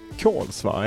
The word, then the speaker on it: Kolsva: Du lämnade Kolsva, är det, så det uttalas? – Ja.